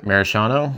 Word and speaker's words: maraschino: It's like overly like a maraschino or how do you say it? Cherry? The maraschino? – Maraschino. Yeah.